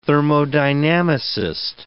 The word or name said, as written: thermodynamicist